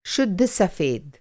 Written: शुद्ध

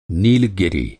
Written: नीलगिरी

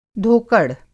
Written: धोकड़

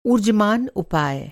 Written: ऊर्जमान

